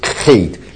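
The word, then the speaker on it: hate (חית): 然后下面黑是一个重音的像德文的哇 ch 的发音，像德文 ch 的发音啊，哇啊，但是它这个读音名是 hate 很重的喉咙重音 hate 啊，hate 这个 t 是等于英文的 t 的发音。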